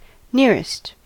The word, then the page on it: nearest: American English